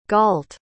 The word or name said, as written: galt